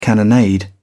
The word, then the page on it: cannonade: British English